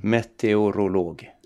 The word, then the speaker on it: meteorolog: Två saker tänkte jag på, dels så insåg jag att jag har nog alltid sagt om ditt yrke metrolog. Kan du säga hur det heter egentligen? Meteorolog. Hur många skulle du säga säger, säger uttalar alla de här eh, vokalerna? – Eh, inte så många.